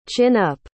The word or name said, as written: chin-up